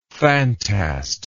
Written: phantast

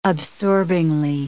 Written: absorbingly

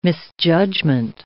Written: misjudgment

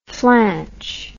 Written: flanch

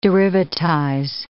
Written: derivatize

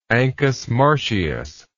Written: Ancus Marcius

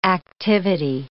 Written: activity

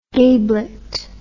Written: gablet

